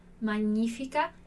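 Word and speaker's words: magnifica: Olha aqui uma palavra interessante pra gente pensar a pronúncia, né? Como é diferente do magnífica, magnífica e magnífica. Então, o nosso GN aqui com o som de NH.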